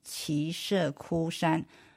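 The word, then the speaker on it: 耆阇崛山: 再按这本书呢，它应该念奇舍窟山，不像我们如果查字典里它是另外发音，那么但是我们按玄应音译，因为它是更准确了啊，它是专门给佛经注音注意的，佛经的相关的词。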